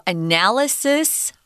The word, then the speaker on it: analysis: So, analysis and analyze. We have the strong syllable on different uh, syllables than those. Sorry about that. Analysis, second syllable analyze first syllable.